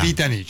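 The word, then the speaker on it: Cvitanich: Que todo el mundo lo pronuncia mal. – Civitanich. – La pronuncia Civitanich es Civitanich. Se ve que acordaron en... mencionarlo así para hacerlo más fácil. – Apellido croata. – Svitanich. – Claro, Svitanich.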